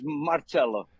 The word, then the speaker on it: Marcello: Marcelo, is it Marcello or Marcello? Am I saying it wrong even? How would you say it? – It's Marcello.